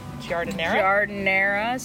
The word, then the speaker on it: giardiniera: Giardiniera.